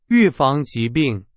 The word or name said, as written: 预防